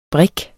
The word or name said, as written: BRIKKERNES